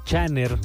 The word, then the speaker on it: Chnnr: Y es un artista que se llama Channer. Se escribe C-H-N-N-R. No tiene vocales.